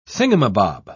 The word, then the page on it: thingumbob: British English